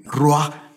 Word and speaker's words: ruach: ruach, se escribe R-U-A-C-H en el hebreo, cuando Dios sopla de dentro de vida, vino el hombre a ser un alma viviente, dice.